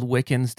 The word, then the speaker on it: Wickens: Um, my last name is spelled Wickens.